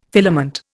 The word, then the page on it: filament: Indian